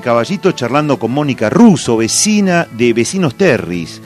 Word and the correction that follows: Terry: Vecinos Terry, ahí lo dije bien, la tercera es la vencida.